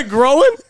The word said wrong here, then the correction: groin: At first, I thought you actually said growing, and then I realized that you meant groin, and you just pronounced it growing. – It's G-R-O-I-N. – Groin.